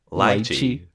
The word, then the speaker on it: lychee: Lychee. No, I'm just pronouncing it the way it should be.